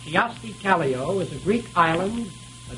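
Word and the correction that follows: Kyösti Kallio: I'll repeat that. Kiyosti Kallio, I'll spell that, K-Y-O-S-T-I-K-A-L-L-I-O, is a Greek island, a dictator of Peru, president of Finland, or the name of a Japanese political party.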